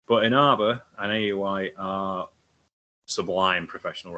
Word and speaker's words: Aoi: AOI. Yes. AOI. It is literally how it's spelled. AOI.